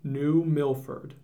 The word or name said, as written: New Milford